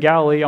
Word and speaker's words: Galilee: Did I say that right? Yeah, they're in Galilee on the Sea of Galilee. I was just making sure I said that right.